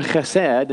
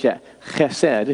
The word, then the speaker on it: chesed: His mercy, you can see it on screen, is a good definition, comes from an old word, chesed, and that's how you say it with that rough H, chesed in Hebrew.